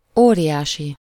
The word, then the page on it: óriási: /ˈoːriʲaːʃi/; /oː.rjaː.si/